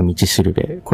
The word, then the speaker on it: 道しるべ: 2024 年、 100 万ドルの、五稜星、と書いて、ま、道しるべ。